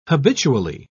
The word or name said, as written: habitually